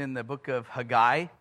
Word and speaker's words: Haggai: Hey, guy, what's up? Haggai. Haggai is how you pronounce it. Simply, the only reason I know that is because I have a Jewish friend whose name is Haggai, and that's how he pronounces his name.